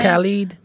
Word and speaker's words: Khalid: That Khalid dude. Is that how you say his name? – Khalid.